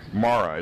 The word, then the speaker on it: Marah: Therefore, it was named Marah. It's actually pronounced Marah in Hebrew. The word Mar, M-A-R, means bitter.